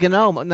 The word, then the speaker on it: GNOME: Now, is it, you pronounce the G? I thought the G was silent. It's just GNOME. But the people. – There's a lot of controversy about that. Folks at the GNOME Foundation pronounce the G for one reason or another, probably to differentiate it from the word gnome that it's spelled like.